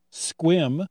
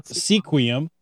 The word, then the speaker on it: Sequim: Port Townsend, Squim. – Yeah. – Or Sequim, as the non-natives like to pronounce it.